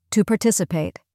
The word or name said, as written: to participate